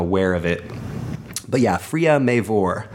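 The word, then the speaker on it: Freya Mavor: It's Freya Maver. Freya Maver. F-R-E-Y-A space M-A-V-O-R.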